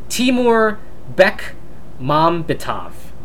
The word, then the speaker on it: Timur Bekmambetov: I'm totally butchering his name. Timur Bekmambetov. Bekmambetov, all right, there you go.